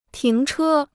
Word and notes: 停车: tíng chē

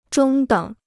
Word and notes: 中等: zhōng děng

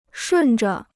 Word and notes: 顺着: shùn zhe

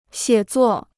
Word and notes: 写作: xiě zuò

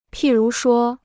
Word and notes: 譬如说: pì rú shuō

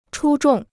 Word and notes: 出众: chū zhòng

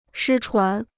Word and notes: 失传: shī chuán

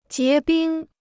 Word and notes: 结冰: jié bīng

